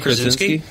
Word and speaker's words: Krasinski: Krasinski. – Yes, sorry about that. – Oh, John Krasinski.